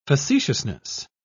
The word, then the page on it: facetiousness: British English